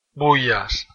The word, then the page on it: Bullas: Spain